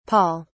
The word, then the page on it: paul: British English